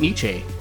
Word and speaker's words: Nietzsche: Nietzsche.